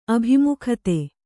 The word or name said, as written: ಅಭಿಮುಖತೆ